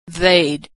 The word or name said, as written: they'd